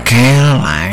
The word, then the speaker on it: Caroline: Mas a pronúncia em inglês, Caroline. – Caroline.